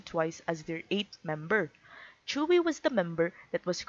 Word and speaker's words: Tzuyu: When I first encountered Tzuyu's name, like a lot of other people that don't speak Korean, I pronounced it as Tzuyu. Then I watched some interviews and variety shows and quickly learned that it's pronounced as Tzuyu.